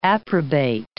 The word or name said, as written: approbate